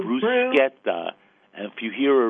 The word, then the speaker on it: bruschetta: It's brew, as in like beer, bruschetta. And the reason it's schetta, and not, I'm not even going to mispronounce it, is because in Italian, CH is a K sound, not a sh sound, K.